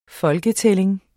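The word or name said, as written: FOLKETÆLLING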